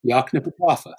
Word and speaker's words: Yoknapatawpha: It's a word my students can never pronounce. About half our first class on Faulkner was getting, okay, say it with me, Yoknapatawpha.